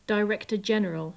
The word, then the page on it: director general: English